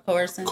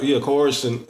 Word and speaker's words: coercion: Now, that's not T.I.'s fault, but that's where the term, what they call it, how do you pronounce it? – Coercion. – Coercion.